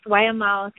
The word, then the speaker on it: Waimau: Did I say that right, Kanani? Waimau? – Yes, Waimau. Thank you, Crystal.